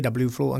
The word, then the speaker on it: VFlow: Jo, o tom vyslovování, on to každý vyslovuje jinak, že správná asi výslovnost je WFlow anglicky, ale už jsme si zvykli, že to každý vyslovuje VFlow a nám to nevadí.